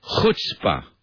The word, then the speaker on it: chutzpah: The word for that kind of speed talking is the word is chutzpah. Chutzpah. Let me spell it for you. C-H-U-T-Z-P-A-H. C-H-U-T-Z-P-A-H. Be careful how you pronounce it. It's pronounced chutzpah.